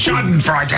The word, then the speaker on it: Schadenfreude: But you got to say it like you're German. Speaking to 10,000 Nazis. Like, Schadenfreude.